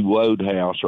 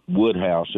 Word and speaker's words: Wodehouse: But I think Tucker is despite his love of P.G. Wodehouse or Woodhouse, as he pronounces it, I think he's demonstrably wrong.